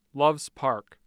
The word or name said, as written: Loves Park